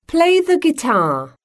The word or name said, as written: play the guitar